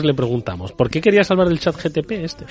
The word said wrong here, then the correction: GPT: GPT, yo siempre lo digo mal.